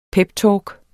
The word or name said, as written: peptalk